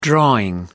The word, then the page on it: drawing: American English